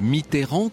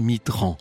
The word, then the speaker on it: Mitterrand: D'abord, il y a ce nom tellement connu des Français, ce nom de Mitterrand que certains s'obstinent contre l'orthographe à prononcer Mitterrand.